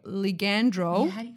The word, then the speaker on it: ligandrol: And she says the ligandrol. – Yeah, that's how you pronounce it.